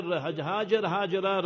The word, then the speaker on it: Hajara: First was Nabi Ismail alayhi salam, his honorable mother, Sayyida Hajar, Hajara radiallahu anha, both ways you pronounce it.